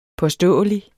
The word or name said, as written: påståelig